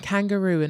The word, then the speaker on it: kangaroo: The stress falls on the last syllable in kangaroo and referee.